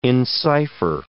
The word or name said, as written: encipher